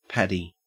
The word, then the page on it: paddy: Australian English